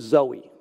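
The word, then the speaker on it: zoe (ζωή): It's actually spelled like the name Zoe, but it's zoe.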